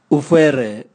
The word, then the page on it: uvwere: Raga